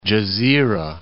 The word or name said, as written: Gezira